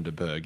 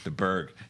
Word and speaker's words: de Bourgh: de Bourgh and her domination. – I'm sorry, I mispronounced that name, de Bourgh.